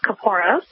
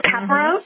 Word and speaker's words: Kaporos: Well, there are several pronunciations. We tend to stick to the pronunciation of Kaporos, but you might hear others refer to it as Caparos or Kaporos. So you'll hear several incarnations depending on who you speak with, but we tend to just say Kapora.